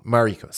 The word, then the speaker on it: Mariko: And then which Makara didn't how do you say her name? Mariko, sorry.